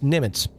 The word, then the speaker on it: Nimitz: I used to be in the Navy, and the first ship I was stationed on was the USS Nimitz. Did I say that right? – You did.